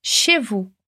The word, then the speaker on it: chez vous: Chez vous. Viu que a gente não pronuncia nem o Z e nem o S no final dessas duas palavras?